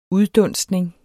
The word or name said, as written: uddunstning